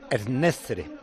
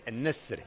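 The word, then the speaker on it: En-Nesyri: Ennezre. Me está enseñando Pedrito Martín cómo se pronuncia Ennesiri. Ennezre.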